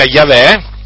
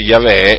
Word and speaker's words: Yahweh: Il tetragramma è formato dalla Y, dalla H, dalla W e dall'H. Ecco, questo è il tetragramma che si pronuncia Yahweh. In ebraico diciamo si pronuncia Yahweh